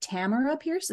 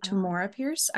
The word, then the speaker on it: Tamora Pierce: how I've just learned you pronounced it Tamara Pierce instead of Tamora Pierce. I was pronouncing it wrong my entire life.